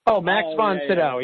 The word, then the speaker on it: Max von Sydow: Max von Sydow, yes.